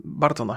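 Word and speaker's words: Burton: Mm, I ja nie jestem takim jakimś ogromnym fanem e, Tima Burtona, chociaż są, są parę rzeczy, Bartona chyba się wymawia, nie? – Mhm.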